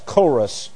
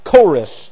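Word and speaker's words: chorus (χωρίς): In fact, the word chorus, which I'll use this up here just for the sake of writing something, C-H-O-R-I-S is how it looks in Greek. C-H-O-R-I-S, chorus. That's the hard C-H, the chi. Chorus.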